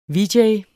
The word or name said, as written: vj